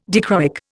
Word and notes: dichroic: Indian

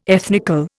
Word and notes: ethnical: Indian

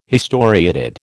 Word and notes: historiated: American English

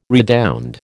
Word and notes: redound: American English

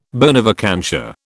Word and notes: bona vacantia: British English

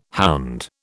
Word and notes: hound: British English

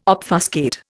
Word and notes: obfuscate: Indian

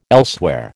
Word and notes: elsewhere: American English

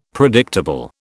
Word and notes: predictable: British English

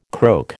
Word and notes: croak: American English

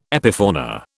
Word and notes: epifauna: British English

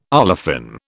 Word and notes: olefin: British English

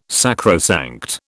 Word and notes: sacrosanct: British English

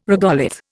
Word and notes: regolith: Indian

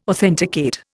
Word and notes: authenticate: Indian

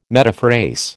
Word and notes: metaphrase: American English

